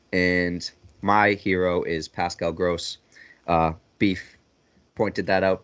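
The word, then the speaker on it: Groß: I knew it was gross, but I, uh, obviously you mistake the B for a B- Grobe, and uh, it can be mistaken.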